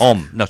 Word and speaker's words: homme: Om. – Om, is it? – H-O-M-M-E, but you don't pronounce the H.